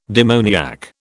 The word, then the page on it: demoniac: British English